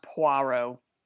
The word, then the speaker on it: Poirot: It's pronounced Poirot.